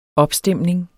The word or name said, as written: OPSTEMNINGER